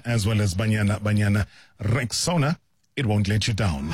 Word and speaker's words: Rexona: It is pronounced Rexona, simple, strong, and easy to remember.